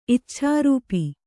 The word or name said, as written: ಇಚ್ಛಾರೂಪಿ